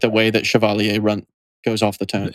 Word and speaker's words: Chevalier: Okay, no, Michael, that is the English pronunciation. – Chevalier. Michael is correct. – Chevalier. It's Chevalier. – It is a French word.